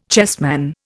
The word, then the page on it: chessman: Indian